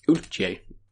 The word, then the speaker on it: Uiltje: But it's a collaboration with Utje, probably. – Yeah. – J-E, right? – Yes. U-I-L-T-J-E. – Utje.